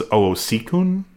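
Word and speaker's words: Owosekun: Burnham and operations officer Joanne, oh my God, how do we pronounce Joanne's last name? Oseekin? – Minowo. – Yeah, I call her OO too, but I think her last name is Oseekun. – Yes, that's right.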